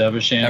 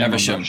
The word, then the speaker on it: Evesham: I'm going to an event in a town called, I say Evesham, but because it's pronounced Evesham. – Evesham. – Sure. Evesham. Evesham. Yeah. It's Evesham. War in the Vales.